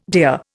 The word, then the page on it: deer: Indian